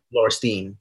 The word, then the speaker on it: Lauristine: But after your last album, Lauristine, I believe is how you pronounce it. Lauristine. Is that correct? Lauristine? – Lauristine. – Lauristine. Yeah. Okay.